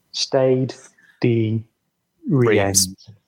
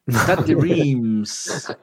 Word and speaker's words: Stade de Reims: Stade de Reims. – Stade de Reims.